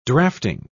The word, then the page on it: drafting: British English